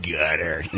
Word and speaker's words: Qatar: And that's a Q-A-T-A-R, but you pronounce it Qatar.